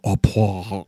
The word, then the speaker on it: au poivre: You got to put your chin on your chest. You go au poivre. So it sounds like you're choking yourself. It's like au poivre because it's French or whatever.